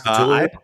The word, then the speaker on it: Cthulhu: How do you pronounce Cthulhu? You pronounce Cthulhu? – Cthulhu is how I pronounce.